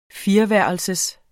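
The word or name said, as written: fireværelsers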